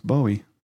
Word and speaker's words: Bowie: I mean, a Bowie knife. – No, it's Bowie. That's like David Bowie? I think you got a thing for David Bowie. – That's I do. – Say Bowie. – Well, it's spelled that way.